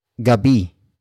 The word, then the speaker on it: gabi: And it's at gabi.